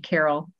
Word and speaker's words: Carole: Well, I'm on LinkedIn, and my first name is spelled with an E, Carol, C-A-R-O-L-E. And the last name I married into that one is S-T-I-Z-Z-A.